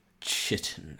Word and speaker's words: chitin: Do we say chitin, chitin, or shitin? – I mean, I've been saying chitin for however long I've been alive, so I'm going to continue saying that until the dictionary changes there. So, chitin.